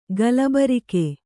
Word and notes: ಗಲಬರಿಕೆ: kannada

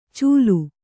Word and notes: ಚೂಲು: Kannada

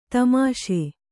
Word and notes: ದಾಮಾಷೆ: dāmāṣe

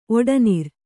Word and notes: ಒಡನೆರೆ: oḍanere

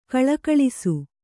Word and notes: ಘುಳುಘುಳಿಸು: ghuḷu ghuḷisu

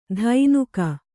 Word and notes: ಧೈನುಕ: Kannada

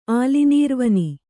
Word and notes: ಆಲಿನೀರ್ವನಿ: Kannada

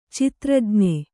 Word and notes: ಚಿತ್ರಜ್ಞ: citrajn`a

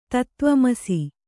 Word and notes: ತತ್ವಮಸಿ: tatva masi

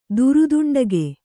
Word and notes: ದುರುದುಂಡಗೆ: kannada

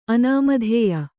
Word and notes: ಅನಾಮಧೇಯ: kannada

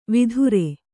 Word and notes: ವಿದೂರ: kannada

